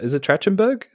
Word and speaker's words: Trachtenberg: Michelle Trachtenberg. Is it Trachtenberg? Is that how you pronounce that? – Yeah.